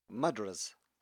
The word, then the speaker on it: mudras: Mudras.